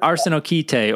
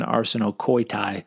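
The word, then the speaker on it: arsenokoitai (ἀρσενοκοῖται): Yeah, arsenokite, or according to the Erasmian pronunciation, arsenokoitai, it seems like a neologism.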